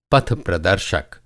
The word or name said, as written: पथप्रदर्शक